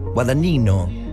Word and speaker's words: Guadagnino: No sé si lo pronuncié bien, Guadagnino. Guadagnino, sí, está bien.